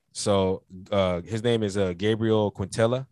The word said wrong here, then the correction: Quintanilla: I think I'm saying it wrong. Yeah. Quintanilla. There we go.